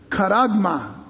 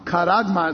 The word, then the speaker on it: charagma: It's the Greek word karagma. Karagma. It's spelled C-H-A-R-A-G-M-A. Karagma.